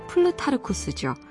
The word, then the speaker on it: 플루타르코스: 흔히 플루타크 영웅전이라고 말하지만 그 이름의 정식 발음은 플루타르코스죠.